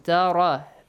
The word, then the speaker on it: تَرَ: أَلَمْ تَرَ إِلَى الَّذِي أَلَمْ تَرَ رَا has, you should pronounce it with a heavy sound.